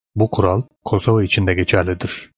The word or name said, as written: kural